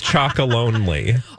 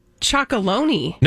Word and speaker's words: Chocolonely: I thought it was Chocolonely. – No, it's Chocolonely. – Is it really? – Yeah. – What? I think I've been saying it wrong all this time.